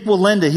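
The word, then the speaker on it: Wallenda: His name is Nick Walenda.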